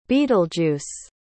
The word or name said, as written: Betelgeuse